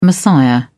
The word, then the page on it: messiah: British English